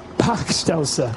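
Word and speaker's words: Bachstelze: A Welschnascher? Is that quite right? It's pretty rubbish pronounce here. Pachtelser. Thank you very much. Better.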